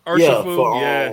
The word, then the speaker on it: Urshifu: You're Urshifu? I can't say his name. – Urshifu? – You are it. – Urshifu, yeah.